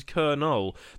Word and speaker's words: Colonel: That word derived from Middle French. The pronunciation is Colonel. The reason it's a strange word for foreigners is because it lacks an R, despite using that sound when spoken. The second O is silent as well, which adds to the confusion.